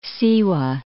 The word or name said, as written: Siwa